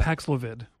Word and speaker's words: Paxlovid: Paxlovid? – Yeah. Paxlovid.